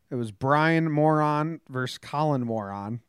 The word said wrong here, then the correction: Moran: It's Moran. Okay. – Okay. – Okay. The real pronunciation is Moran.